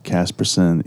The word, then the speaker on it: Casperson: My last name has always been spelled S-O-N by folks who don't know it's spelled S-E-N. Casperson, you know, it's just, I don't know, it's phonetic.